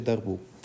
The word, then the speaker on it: Darboux: Allora, questo si chiama riferimento di Darboux, che è un francese e si legge Darboux.